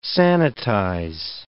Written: sanitize

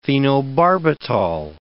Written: phenobarbital

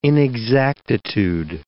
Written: inexactitude

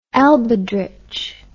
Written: elbedritsche

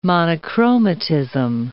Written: monochromatism

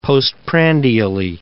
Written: postprandially

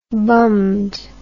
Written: bummed

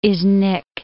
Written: Iznik

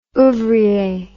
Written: ouvrier

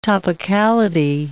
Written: topicality

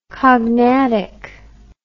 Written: cognatic

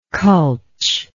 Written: culch

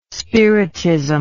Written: spiritism

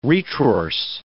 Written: retrorse

